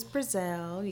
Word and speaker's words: Breazelle: My name is Breazelle. You can find me on Instagram. – And that's spelled, it's not Brazil like the country.